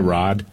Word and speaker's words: Raad: R-A-A-D. What's the pronunciation of that? Rod? – Yeah, Rod.